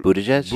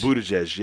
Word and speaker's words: Buttigieg: Pete Buttigieg. – Buttigieg.